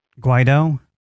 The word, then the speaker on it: Guaido: Guido. – Guido? – Yeah. – How do you spell that? – G-U-A-I-D-O. – E-U-I-D-O? – Mm-hmm.